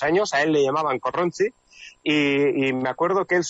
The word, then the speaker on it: Korrontzi: Por cierto, Corronzi, no sé cómo pronunciarlo, es precisamente el primer disco, ¿no? – Efectivamente, sí, el grupo se llama Corronzi. – Corronzi. – Decimos así, Corronzi.